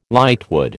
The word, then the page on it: lightwood: American English